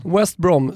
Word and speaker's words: West Brom: Men, men det är W. Engelska W. – Ja, West Brom.